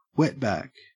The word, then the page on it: wetback: Australian English